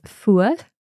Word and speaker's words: fuar: So the word fuad is spelt F-U-A-R. – Yeah. – The R at the end can be pronounced differently depending on which part of the Gaelic speaking world you're in. – Okay. – I would say fuad, which, so I'm forming that R quite far forward in my mouth.